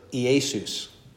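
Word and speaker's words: Iesus (Ἰησοῦς): Except you'd pronounce E, because that's not a diphthong. The iota and eta don't form a diphthong, so you pronounce both of them. So it's Iesus.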